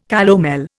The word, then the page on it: calomel: Indian